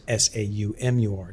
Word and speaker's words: Saumur: And also, just people listening, you should try Samur, and then the little village of Champigny, spelled like Champ, Igny, and Samur is Saumur, just so you don't go in asking for s'mores at the wine store.